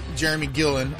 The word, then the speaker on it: Jeramey Gillilan: Up first, we will be talking with Jeremy Gillen of the Tortillas and Takes podcast.